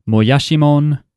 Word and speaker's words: Moyashimon: Anyway, so yes, this week we are going to be talking about God damn it, I keep wanting to say it like it's an English word. Moyashimon,